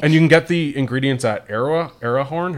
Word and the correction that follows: Erewhon: How do you say that? – Erewhon. Yeah.